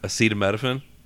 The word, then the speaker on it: acetaminophen: Actually, I just read it wrong. Acetaminophen. – Right. – I just switched two letters around, and like if you switch those two letters around, it's asymptotophin.